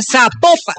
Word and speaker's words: Zapopan: Oiga, nos escriben los oyentes y que no se pronuncia Zapopan, sino que se pronuncia Zapopan. – Ah, Zapopan.